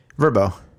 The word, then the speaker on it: VRBO: Verbo. – Yeah. Apparently, that's how you're supposed to pronounce it.